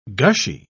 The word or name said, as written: gushy